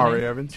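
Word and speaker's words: Jahri Evans: Jahari Evans.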